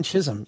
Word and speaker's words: Chisholm: Yeah, it's in Chisholm.